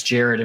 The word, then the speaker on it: Jared: my birth given name was Jared, and we spell it the same way.